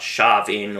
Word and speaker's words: Samhain: But I'm simply pointing out, though, the true origins of the jack-o'-lantern and busting the myths about Sharvin or Samhain, as it's spelt, and showing the true origin of Halloween is All Hallows Eve.